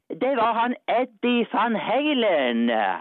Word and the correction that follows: Eddie Van Halen: Ja, ikke helt 100% riktig. Jeg tror faktisk at det uttales Eddie Van Halen.